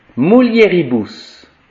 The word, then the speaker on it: mulieribus: In mulieribus. Donc, on applique bien la règle. U, c'est ou, et E, c'est E. Mulieribus.